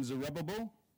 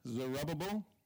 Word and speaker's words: Zerubbabel: Then Zerubbabel, that's how you say it, where is L? Zerubbabel, son of Shealtiel, Joshua, son of Jozadak.